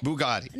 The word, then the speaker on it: Bugatti: Bugatti.